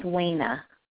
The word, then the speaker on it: Wayna: My name is Wayna.